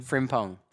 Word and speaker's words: Frimpong: On Instagram, just at Tarik Frimpong, so T-A-R-I-K, F-R-I-M for Mary, P-O-N-G. – How do I say your last name? – Frimpong. – Frimpong. – Yes. – Like Frim. Pong. – That's it.